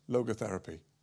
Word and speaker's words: Logotherapy: And what Frankl said, Frankl developed a type of psychiatry that's Logos theory. Am I saying it right? Logotherapy.